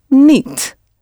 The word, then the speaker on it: niet: The word for not is niet. N-I-E-T. The I-E sound always pronounced as E.